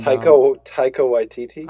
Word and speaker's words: Taika Waititi: I mean, obviously it's Jermaine Clement from Flight of the Conchords and Taiki Waititi, whatever. And Taika Waititi.